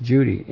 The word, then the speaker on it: Cudi: In the case of Mount Judy, we saw that C-U-D-I was pronounced Judy in Arabic. So the C is pronounced with a softer sound, just like the G can be pronounced softly, as in genie, spelt genie, but it's pronounced softly.